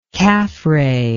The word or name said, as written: Khafre